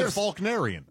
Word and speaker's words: Faulknerian: There's no L. – I don't pronounce. You don't pronounce. You say Faulkner. – Right. – It's a Faulknerian.